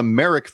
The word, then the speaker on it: Merrick: And one of our freelance writers, Kevin Revolinsky, says that since the 1890s, the Merrick family, rhymes with Derek, has had Merrick's Bar, and that he would say that that is some of the best fish fry that you will find.